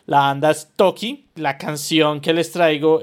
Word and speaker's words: Taake: Entonces, de Toki, que se escribe Taake, y todo el mundo le dice Taake pero los manes lo pronuncian Toki.